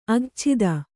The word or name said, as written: ಅಘಚ್ಛಿದ